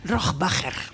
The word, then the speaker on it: Rohrbacher: Rohrbacher.